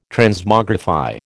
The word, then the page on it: Transmogrify: American English